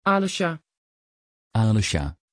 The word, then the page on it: Alesja: Dutch